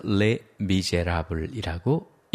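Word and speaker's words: Les Misérables: 물론 프랑스에서는 끝에 있는 s는 자음은 읽지 않습니다. 그래서 le miserable이라고 읽는 거죠.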